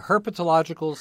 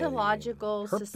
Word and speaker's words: Herpetological: Virginia Herpetological Society. – Herpetological.